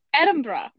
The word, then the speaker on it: Edinburgh: It's Edinburgh, right? – Yeah, you got it.